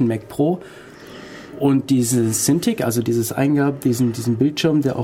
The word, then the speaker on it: Cintiq: Cintiq.